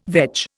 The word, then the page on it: veg: Indian